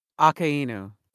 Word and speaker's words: Akainu: Akainu? – Akainu.